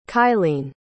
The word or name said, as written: kylene